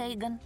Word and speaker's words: Эйгон: Это слово значит Эйгон. Когда вы видите такие буквы вместе, как здесь, это читается как Эйк.